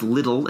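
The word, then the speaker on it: Liddell: I think the last name is pronounced Little. It's L-I-D-D-E-L-L.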